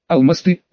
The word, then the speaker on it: Алмасты: Алмасты — ударение на последнем слоге.